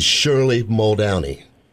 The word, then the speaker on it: Shirley Muldowney: Ladies and gentlemen, let me introduce you to Mrs. Shirley Muldowney.